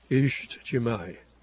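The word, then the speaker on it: İctimai: Many thanks to Patrick in Poland, who says, I don't know Azerbaijani much, but my hobby is linguistics, and it's pronounced something like Ishtjimai, and he explains more about it.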